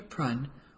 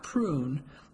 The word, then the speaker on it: Prunn: Jenny, or Eugenia Prunn, was one half of the perpetually ancient Prunn sisters. Prunn, pronounced Prun, not prune, like generations of kids had called the former educators.